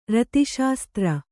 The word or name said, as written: ರತಿಶಾಸ್ತ್ರ